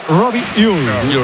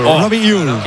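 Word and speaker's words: Robbie Ure: A ver, el club pasó incluso un audio, lo ha pasado. Y en concreto sería Robi Ui. Robi Ui.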